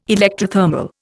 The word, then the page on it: electrothermal: Indian